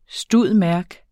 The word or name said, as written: stud.merc.